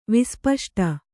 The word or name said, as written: ವಿಸ್ಪಷ್ಟ